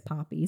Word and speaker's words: Poppe: So Poppe is my last name. That's how you say it, is Poppe. So you did a good job.